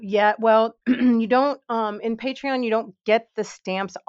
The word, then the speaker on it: Patreon: And so this will be like a subscription service where if you're on Patreon, you get like, am I saying that right? Or am I saying the alcohol again? – Patreon. Patreon.